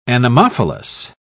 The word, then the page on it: anemophilous: British English